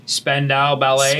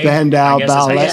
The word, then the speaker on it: Spandau Ballet: Spandau Ballet. – Spandau Ballet.